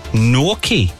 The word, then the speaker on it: gnocchi: Gnocchi, yes.